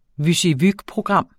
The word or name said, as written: WYSIWYG-program